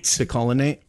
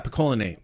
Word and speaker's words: picolinate: But I've read that taking a zinc, it's not polyacotinate. I'm not going to say it. Maybe I did say it right. – Picolinate. – Oh, yeah. Picolinate. Thank you.